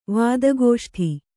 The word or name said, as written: ವಾದಗೋಷ್ಠಿ